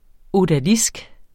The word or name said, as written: ODALISKEN